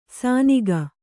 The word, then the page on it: ಸಾನಿಗ: sāniga